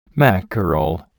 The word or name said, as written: mackerel